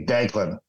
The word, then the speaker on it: Deaglan: Well, what we say in the business, the government name, the one that's actually on his birth certificate, passport, driver's license, is the Irish spelling, which is D-E-A-G-L-A-N, which is basically Declan, which is just a hard pronunciation of the G, so it comes across as a C.